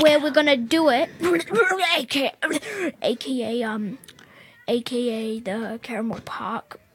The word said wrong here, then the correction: Campbell Park: Campbell Park, not Caramel.